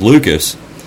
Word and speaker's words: Lucas: Well, and my last name is Lucas, and it's not that people really mispronounce that, but a lot of people have tried to say it's Lucis. Because mine is spelled L-U-C-A-S, and a lot of Lucas is spelled L-U-K-A-S.